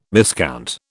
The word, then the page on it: miscount: British English